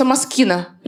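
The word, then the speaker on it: Moschino: Я через 2 недели узнала, что правильно читается «маскина». Прикиньте, я 2 недели ходила, «машина» называла.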